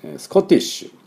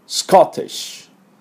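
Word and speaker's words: Scottish: で今日はそのスコットランドね、スコットランドでできたということなんで、スコットランドのね、スコティッシュっていうんです。スコットランド人のことをね、またスコットランドのっていう意味もありますね。ジャパニーズと同じなんですが、この発音やっていきましょうか。こんな音になります。スコティッシュ、スコティッシュ、はい、スペルは S、大文字の S、 C-O-T-T-I-S-H です。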